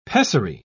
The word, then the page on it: pessary: British English